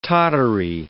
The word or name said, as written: tottery